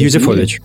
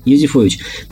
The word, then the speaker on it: Юзефович: Ну да, как сказала, как сказала Галина э, Юзефович, Юзефович, я, к сожалению, не знаю, как произносится. – Юзефович. Юзефович.